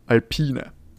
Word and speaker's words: Alpine: Wir reden nämlich heute über Renault, beziehungsweise ab dieser Saison trägt es den Namen Alpine. Wir haben natürlich auch schon drückt Alpine, war jetzt das die richtige Aussprache, richtig? Haben wir ja schon damals diskutiert. – Leider nicht, Alpine. Alpine. Alpine.